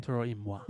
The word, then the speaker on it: Toro y Moi: Toro y Moi.